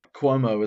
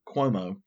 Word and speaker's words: Cuomo: And the NRA felt forced to go and file this lawsuit saying that the Department of Financial Services and Andrew Cuomo um, putting all this pressure. Is that how you say it? Mm-hmm.